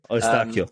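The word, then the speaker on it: Eustáquio: But one other player that I'm actually really interested in and excited to see play is Escocito for Porto, sorry. – Estacio. – Centre midfielder. Estacio. There's my Welsh pronunciation being proven wrong.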